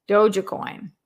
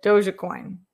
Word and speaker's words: Dogecoin: Kenny says pronounce Dogecoin. Dogecoin. Like Doja Cat, but Dogecoin. Maybe. Unless I'm still mispronouncing it.